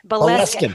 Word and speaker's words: Boleskine: Oh, and you went to, would you please tell me the pronunciation of Jimmy's and Aleister Crowley's place in Scotland? Is it Boleskine? – Boleskine House. Excellent. Yay.